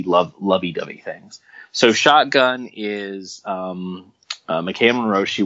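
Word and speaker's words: Maika: Is it Makaya? It's like M-A-K-I-A. Makaya. Sure.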